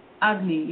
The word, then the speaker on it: Agni: Now, the next question that I have for you is, I don't know if I'm going to pronounce this correctly, the Agni, is it? – Agni, yeah.